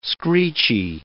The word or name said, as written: screechy